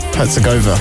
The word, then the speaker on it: Herzegovina: Greetings from sunny Sarajevo in Bosnia and Herzegovina. I think that's how you say it. – Yeah, where? – Herzegovina. – Oh, I nearly got it right.